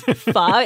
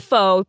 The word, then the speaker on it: pho: Especially ones that, you know, imply that pho rhymes with show. Or that it's pho. Yeah. – Oh, yeah. That is my biggest pet peeve. It's pho. It's not pho.